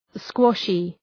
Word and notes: squashy: ‘skwɒʃı

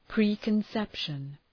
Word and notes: preconception: ,pri:kən’sepʃən

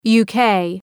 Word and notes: UK: ,ju:’keı

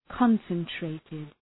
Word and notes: concentrated: {‘kɒnsən,treıtıd}

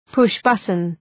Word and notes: push button: ‘pʋʃ,bʌtən

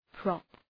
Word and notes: prop: prɒp

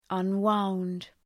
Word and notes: unwound: ʌn’waʋnd

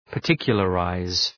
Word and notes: particularize: {pər’tıkjələ,raız}

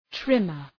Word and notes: trimmer: ‘trımər

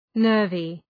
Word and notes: nervy: ‘nɜ:rvı

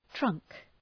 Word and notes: trunk: trʌŋk

